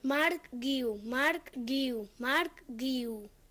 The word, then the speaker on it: Marc Guiu: Si le añades una U, guiu, ¿de acuerdo? Si la joven promesa del Barça se llama Marc Guiu, es exactamente igual de fácil de pronunciar que en castellano.